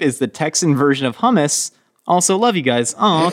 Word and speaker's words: hummus: Anyways, fine. Hummus. – Hummus. – That's how it's pronounced.